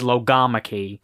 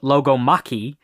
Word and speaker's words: logomachy: But the current like accepted pronunciation is Logomachy because of like biology, like those kind of Greek words where the emphasis is on the second syllable.